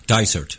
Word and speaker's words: Dysart: He plays the role of Dysart. – Dysart. – Dysart? Okay. – The way we're saying it.